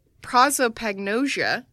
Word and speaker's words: prosopagnosia: Oh, God, how do you pronounce this? I didn't think this through. Prosopagnosia. – Yeah, that sounds right.